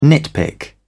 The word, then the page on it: nitpick: British English